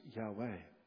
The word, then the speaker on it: Yahweh: The name in Hebrew, which we pronounce as Yahweh.